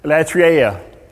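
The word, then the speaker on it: latreia: Latria. – That's right. Sounds right to me. – Latria is found only five times in the New Testament.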